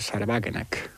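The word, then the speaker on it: Sahra Wagenknecht: Porque hay, para mí, el experimento de partido más interesante que se está dando en la Unión Europea, por cierto, también putinista, es el de Sarwagenegg, o como claro. se diga en alemán, ¿no? – Está bien pronunciado, ¿eh? – Sí.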